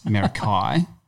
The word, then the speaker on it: Meraki: Yeah, I went with Meraki and it's not Meraki, it's Merakai.